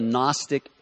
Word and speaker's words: Gnostic: There's something called the Gnostic heresy. It's spelled with a G, G-N-O-S-T-I-C, the Gnostic heresy.